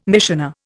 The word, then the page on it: missioner: Indian